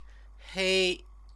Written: hei

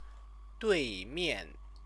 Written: duimian

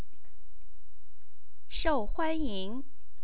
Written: shouhuanying

